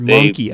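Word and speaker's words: Mynci: And they like Gorky's Zygotic Minci, Or monkey. I think it's pronounced monkey. I think I was corrected afterwards. Gorky's Exotic Monkey. Yeah. – Well, I've always called him Mincy.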